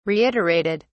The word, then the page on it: reiterated: British English